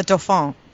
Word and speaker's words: Dauphin: and then the Dauphin.